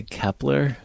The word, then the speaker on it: Kep1er: Did you say Kepler? – Yes. – There's a group named Kepler? – K-E-P-1-E-R. No oh. – L. – Okay. – For one instead, but it's still pronounced like that.